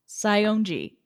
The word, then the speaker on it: Saionji: But also, like, so this is really a douchebag move of Sanji. I can't I don't know why I cannot say his name right. Saionji. There we go.